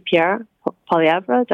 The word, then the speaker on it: Pierre Poilievre: And it probably will be Pierre Poilievre. Did I pronounce his name correctly? – Yep.